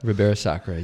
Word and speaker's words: Ribeira Sacra: Valdeorras and Ribeira Sacra. – Ribeira Sacra, yeah.